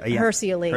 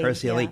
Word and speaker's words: Hirsi Ali: Hirsi Ali. – Hirsi Ali.